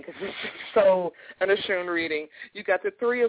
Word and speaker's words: Oshun: So we're going to Mother Goddess Oshun on her powerful day to see what she has to say to her daughter. Am I saying the name right, Ayana? – Yes, you are.